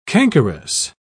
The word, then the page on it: cankerous: British English